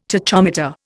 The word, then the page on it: tachometer: Indian